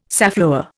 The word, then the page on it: safflower: Indian